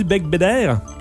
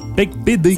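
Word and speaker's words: Beigbeder: Donc, tu nous parles de quoi aujourd'hui, Bec Bédère? – Non. – Bec de Bébé? – Je parle d'un livre de Frédéric Bec Bédé. – Bec Bédé. – Ça s'écrit E-R, mais ça se prononce E.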